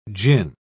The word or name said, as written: gin